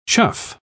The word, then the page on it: chough: British English